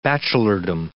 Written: bachelordom

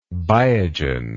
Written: biogen

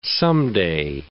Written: someday

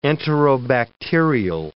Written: enterobacterial

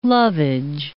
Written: lovage

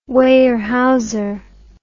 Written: Weyerhaeuser

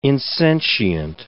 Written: insentient

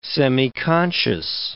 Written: semiconscious

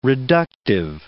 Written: reductive

